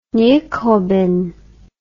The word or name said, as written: nykobing